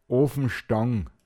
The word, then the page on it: o(n)zintn: Pinzgauer Mundart